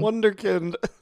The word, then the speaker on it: Wunderkind: And then I love that when he asks Siri about things, Siri calls him the Wunderkind. – Wunderkind, the German pronunciation.